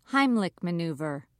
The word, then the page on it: heimlich maneuver: British English